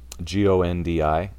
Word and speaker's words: Gondi: The Canada Goose Gondi. I think I'm pronouncing it right. – Yeah, it was like it's like a matzo ball soup, but it's the Persian Jews call it Gondi, mm-hmm. – Gondi.